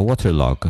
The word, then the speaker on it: Waterlogue: Nie podoba mi się fakt, że nie ma foro extension, więc muszę ją osobno uruchamiać, dlatego rzadko z niej ostatnio korzystam oraz ostatnia Waterlog. E, pisze się Waterlogue.